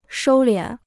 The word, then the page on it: 收敛: shōu liǎn